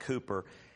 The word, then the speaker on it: Cowper: It's spelled Cowper, but you pronounce it Cooper.